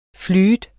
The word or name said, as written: flute